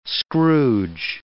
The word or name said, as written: scrooge